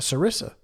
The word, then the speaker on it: sarissa: How many stonefish go into a sarsia or sarisi? Excuse me, a sarisa, a sarissa. Spelled like Marissa with an S at the beginning.